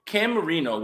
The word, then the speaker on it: Marino: Well, Cam Marino, which I thought it was a parody account. Cam Marino rhymes with Dan Marino.